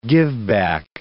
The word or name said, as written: giveback